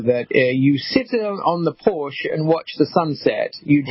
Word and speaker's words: Porsche: We say Porsche, but it's not Porsche. What's the correct way to pronounce it's, P-O-R-S-C-H-E? – Yeah, Porsche.